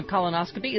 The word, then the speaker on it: colonoscopy: Colon with a long O can be said with the A or the long O when it's expanded to colonoscopy. Think of the sound of the second vowel in harmony versus harmonic. Idiot versus idiotic. Jim and Chris need to go back to school.